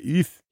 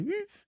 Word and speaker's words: oeuf: So by the way, how do you say egg? And he said, oeuf. And I said, how? And he said, oeuf. And he started making subsequently smaller and smaller noises with a vague F sound at the end.